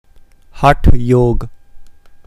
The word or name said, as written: Hathayoga